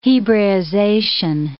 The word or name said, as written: hebraization